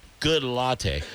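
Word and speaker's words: Goodlatte: The very thought makes me nauseous, said House Judiciary Committee Chairman Bob Good Latte. That's how I pronounce it.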